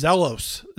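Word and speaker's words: zelos (ζῆλος): The Greek word is zelos, Z-E-L-O-S, is how we would pronounce it.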